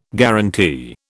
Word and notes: guarantee: British English

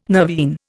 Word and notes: nervine: Indian

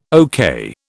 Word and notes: okay: British English

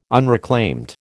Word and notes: unreclaimed: American English

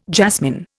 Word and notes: jasmine: Indian

